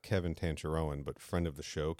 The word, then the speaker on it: Tancharoen: And in an interview, he's introduced as Kevin Tancharoen. So that's the pronunciation I tried to go with throughout the episode.